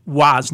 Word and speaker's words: Roy: We knew who the real hockey fans were and who the front runner hockey fans were by how they pronounced Patrick Waugh's name. – Patrick Waugh. – If they pronounced it Patrick Roy, we knew, they're new.